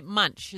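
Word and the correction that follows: Moench: Is that right? – Well, it's close. Nobody ever gets it right. – Minch. – It's pronounced Minch. – Minch. Dave got it right.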